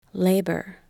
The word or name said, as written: labour